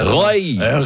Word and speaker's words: Rieu: Og hvordan uttalte dere? – Roy! – Roy! – Roy, roy! – For han nederlandsk.